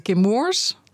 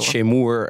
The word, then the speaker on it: Chemours: Want het is, heel vaak wordt het als Chemours uitgesproken. Maar hoe is het? – Nou, wij zeggen Chemours. Op zijn Amerikaans. – Gemoers. – Want de moedermaatschappij zit in Amerika. – Ja, precies. Dus niet Chemoer en andere. Zo minder Frans.